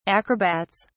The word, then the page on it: acrobats: British English